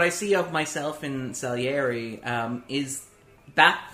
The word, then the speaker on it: Salieri: I'd say what resonates for me with Scaliari. When I look at Scaliari and I see... – Salieri. – Salieri.